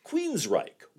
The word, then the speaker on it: Queensrÿche: A misspelled name, and an unnecessary, and in this case, completely unpronounced, umlaut. Yes, sir, that's Queensryche. One word, Q-U-E-E-N-S-R-Y, with an umlaut, C-H-E.